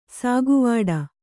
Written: ಸಾಗುವಾಡ

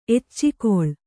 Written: ಎಚ್ಚಿಕೊಳ್

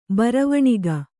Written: ಬರವಣಿಗೆ

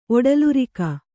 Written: ಒಡಲುರಿಕ

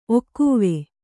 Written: ಒಕ್ಕೊವೆ

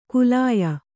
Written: ಕುಲಾಯ

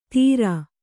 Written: ಧೀರ

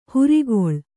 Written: ಹಿರಿಕೊಳ್

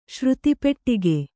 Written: ಶ್ರುತಿಪೆಟ್ಟಿಗೆ

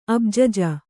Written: ಅಬ್ಜಜ

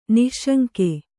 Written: ನಿಃಶಂಕ

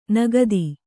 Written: ನಾಗತಿ

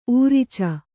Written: ಊರಿಚ

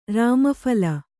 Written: ರಾಮಫಲ

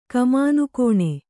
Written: ಕಮಾನುಕೋಣೆ

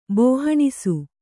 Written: ಬೋಹಣಿಸು

